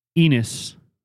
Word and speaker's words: Ennis: So this is Willie's Distillery, Bighorn Bourbon, Willie's Bighorn Bourbon out of Ennis, Montana. And it is spelled E-N-N-I-S, just like penis without the P, added an extra N.